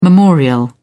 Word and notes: memorial: British English

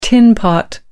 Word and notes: tinpot: American English

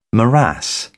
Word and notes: morass: British English

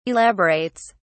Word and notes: elaborates: British English

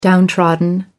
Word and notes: downtrodden: American English

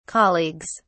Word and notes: colleagues: British English

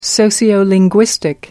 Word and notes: sociolinguistic: American English

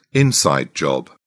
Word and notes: inside job: British English

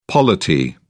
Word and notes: polity: British English

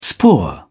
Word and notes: spore: British English

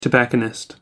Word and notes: tobacconist: American English

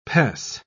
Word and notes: pes: British English

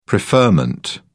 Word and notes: preferment: British English

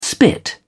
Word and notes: spit: British English

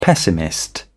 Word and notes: pessimist: British English

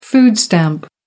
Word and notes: food stamp: American English

